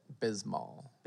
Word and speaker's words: Bismol: Is that how you say it? – Bismol.